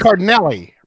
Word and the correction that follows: Cardellini: Cardellini. No, no. I googled how to pronounce that name, and it gave me Cardellini.